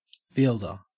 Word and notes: fielder: Australian English

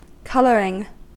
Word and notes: colouring: American English

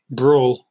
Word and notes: brawl: British English

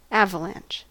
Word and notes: avalanche: American English

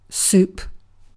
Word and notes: soup: British English

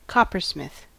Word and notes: coppersmith: American English

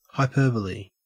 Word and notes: hyperbole: Australian English

